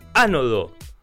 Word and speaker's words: ánodo: Hola, dice, al termotanque se le cambia el ánodo, con acento en la A.